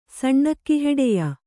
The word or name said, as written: ಸಣ್ಣಕ್ಕಿಹೆಡೆಯ